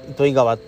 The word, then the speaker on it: 糸魚川: あの糸に魚に川って書いて糸魚川糸魚川って読むらしいです、